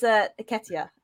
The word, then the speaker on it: Nketiah: and I'm probably going to butcher his name, but that is Nketiah. Have I said it right? – You said that right.